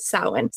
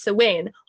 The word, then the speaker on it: Samhain: According to some neo-pagans, who tend to celebrate Samhain, sometimes pronounced Samhain, on the 1st of November, since it historically marks the end of harvest at the beginning of winter.